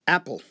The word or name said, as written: apple